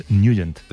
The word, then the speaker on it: Nugent: Hombre, siempre lo hemos llamado en el barrio Ted Nugent o Ted Nugent. – Claro, yo era de Ted Nugent. – Pero la pronunciación correcta es Nugent. – Te fuiste a Washington y descubriste que era Nugent.